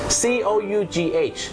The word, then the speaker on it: cough: คุณผู้ชมครับภาษาอังกฤษเป็นภาษาที่บางครั้งเนี่ยนะครับการออกเสียงไม่แมทกับการสะกดอย่างแรงนะครับเช่นคำว่าอาการไอหรือมีการอาการไอนั่นเองครับ C O U G H ออกเสียงว่า cough